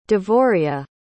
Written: devoria

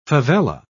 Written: favela

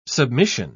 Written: submission

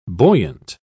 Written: buoyant

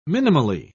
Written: minimally

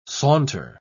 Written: saunter

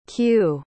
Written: kyu

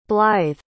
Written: blythe

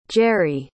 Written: jere

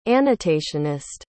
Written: annotationist

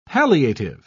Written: palliative

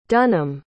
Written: Dunham

